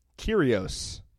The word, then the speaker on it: Kyrgios: Kyrgios. The G is silent.